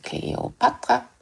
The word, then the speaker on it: Kleopatra: Och de grekiska namnen uttalas ofta med betoning på tredje stavelsen. Alexander, Dionysos och också Kleopatra. Men många tror att jag har lite talfel när jag säger så.